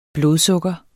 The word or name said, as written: blodsukker